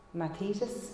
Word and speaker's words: mathetes: The Greek word for disciple is, I think this is how you say it anyway, it's mathetes.